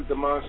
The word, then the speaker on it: Dimanche: Uh, yeah, last last name is Dimanche, and, and it's former, formerly um.